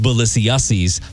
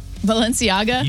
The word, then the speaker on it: Balenciaga: You're gonna have to help me with this, because all I know is Balenciennes, but that's not how you say it. – Balenciaga? – You yes. nailed it.